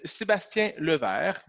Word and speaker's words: Sébastien Levert: My name is Sébastien Levert, which actually means Sebastian.